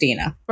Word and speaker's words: Dina: What I tell people is Tina with a D. So it is pronounced Dina.